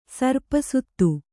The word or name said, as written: ಸರ್ಪಸುತ್ತು